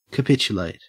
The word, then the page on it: capitulate: Australian English